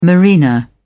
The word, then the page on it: marina: British English